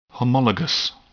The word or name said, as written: homologous